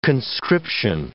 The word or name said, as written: conscription